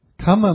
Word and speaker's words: kamma: Make sure you get your word right. So karma, K long A M A, is actually lust or sensual desire. Kamma means action, and kamma means the actions that you make.